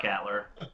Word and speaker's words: Katler: Oh, Scott Catler. – Spelled with a K? K-A-T-L-E-R? – K-A-T-L-E-R.